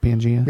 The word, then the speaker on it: Pangaea: Pangaea? – Yeah.